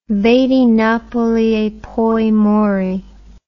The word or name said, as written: vedi Napoli e poi muori